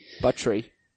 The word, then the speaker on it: Buttrey: And that of course is Ty Buttrey. – Buttery. – but I tried to confirm the pronunciation. I hope that's true.